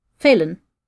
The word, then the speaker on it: Falun: Little entry is, mysterious accidents plague the Swedish mining town of Flaun. I don't know if I said that right. F-A-L-U-N. – Oh, okay. – Falun? Falun? Falun. – Falun. F-F-A... – L-U-N. – Falun. Yeah, yeah, sure.